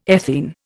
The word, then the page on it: ethene: Indian